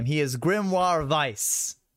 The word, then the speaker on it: Vice: It's Vice. – Vice. Vice. – But even though it's a W. Even though it's a W, they say Vice. – It's spelled with like German. They do say Vice.